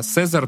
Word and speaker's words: Casier: Ее зовут Эрика де Сезар. Де Сезар? Да, Сезар пишется как C-A-S-I-E-R.